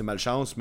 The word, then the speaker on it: Malchance: Imagine, ça se prononce de même, c'est Malchance, mais en anglais.